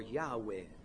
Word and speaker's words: Yahweh: He speaks about Jehovah, or Yahweh, as they say it should be pronounced.